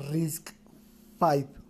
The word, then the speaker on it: RISC-V: RISC V. Se escribe RISC-BV, pero la B realmente quiere decir el número 5 en romano.